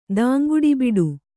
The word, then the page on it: ದಾಂಗುಡಿಬಿಡು: kannada